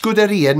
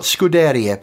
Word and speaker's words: Scuderia: It's pronounced Scuderia, not Scuderia, by the way. Scuderia. It rhymes with diarrhea.